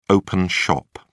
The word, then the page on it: open shop: British English